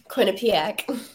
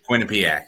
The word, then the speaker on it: Quinnipiac: I already forgot how to pronounce the name. Was it um, uh, Quinnipiac? Uh, Quinnipiac. – Quinnipiac. There it is, Quinnipiac.